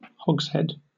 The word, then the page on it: hogshead: British English